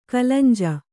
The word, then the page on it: ಕಲಂಜ: kalanja